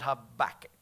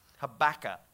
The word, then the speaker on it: Habakkuk: Let's go over to this little book called Habakkuk, just real quick. Go to Habakkuk 1:13, baby. Habakkuk. Is that how you pronounce Habakkuk? Habakkuk. Sounds like tobacco.